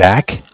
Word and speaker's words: Zach: Yeah, my name is Zach, spelled Z-A-C-H. – Okay. – I'm calling with Bank of Cardiff. Cardiff is spelled C-A-R-D-I-F-F.